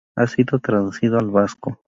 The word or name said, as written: vasco